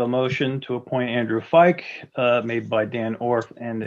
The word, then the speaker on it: Feick: I move to have Andrew, I'm going to mispronounce his last name, so I'll spell it F-E-I-C-K. – Fike. – Andrew Fike. – Fike, to be the alternate member to the Zoning Hearing Board for a term ending December 31st, 2023.